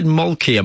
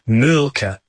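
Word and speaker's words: Mölkky: We use Malky. Most of the people we play against use Malky, but the Finns have a very unusual pronunciation, which, please forgive me, I'll try it. It's Mulka. So I think that's, something a bit more like that. But to us, it's Malky.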